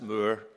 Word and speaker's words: More: It concerned the life of Sir Thomas Moore, spelt M-O-R-E, but pronounced Moore in English.